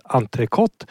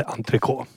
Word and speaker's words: entrecote: Eftersom det är ett e efter t ska det T uttalas entrecote och inte entrecote.